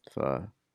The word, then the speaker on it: pho: Pho. – Pho is the right way to say it.